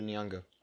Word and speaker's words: Nyong'o: Nyong. I think it's Nyong. Lupita Nyong. – Nyong'o. It's an O. There's an O. Lupita Nyong'o.